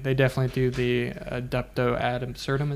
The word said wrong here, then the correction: reductio ad absurdum: Is that how you say it? – Reductio ad absurdum? – Yeah, that's what it is.